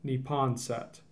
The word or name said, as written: Neponset